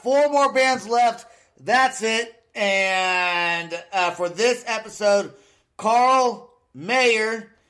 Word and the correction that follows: Meyer: and I hope I said that right. Meyer. Oh, I did. Fuck, I said Mayer again. And I even read it. I'm like, I'm going to say it right. I'm going to say it right. And I still said it wrong. I still. Carl Meyer.